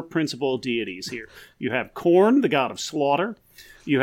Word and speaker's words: Khorne: Nope, it's spelled K-H-O-R-N-E. – Oh, but it still sounds like corn, like maize. – It has, yeah, it's. I did not mispronounce it. I pronounced it 100% correctly. It sounds exactly like the food and or band.